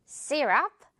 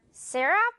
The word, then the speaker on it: syrup: 영어로는 뭔가 이거 Y 때문에 이거 혹시 영어로는 syrup이라고 읽어야 되나? 고민할 수도 있어요. 그렇지만 syrup 이렇게 발음이 됩니다. Y인데 이 발음 난다는 거. syrup.